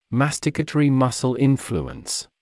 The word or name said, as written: masticatory muscle influence